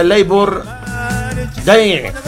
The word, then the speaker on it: Labor Day: Dice, para que no batalle, se pronuncia Labor Day.